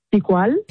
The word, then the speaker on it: Picual: Picual.